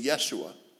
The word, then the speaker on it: Yeshua: His name in the Hebrew is actually Yeshua.